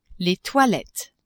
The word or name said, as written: toilettes